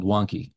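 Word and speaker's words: Vanke: Right now, we've got the best of the best, the company called Wonky. It's spelled with a V, but it's pronounced with a W.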